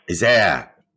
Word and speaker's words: Isère: I can't pronounce it, but it's the Isère. – Isère.